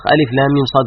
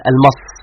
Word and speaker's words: ألف لام ميم صاد: نقول هذه الأحرف الأربعة التي تنطق ألف لام ميم صاد وليس المص، وإنما ألف لام ميم صاد، تبين لنا أن القرآن كتاب المعرفة الواضحة.